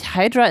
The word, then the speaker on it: Hydra: Hydra ist die englische und Hydra halt die deutsche Aussprache.